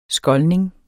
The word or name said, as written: skoldning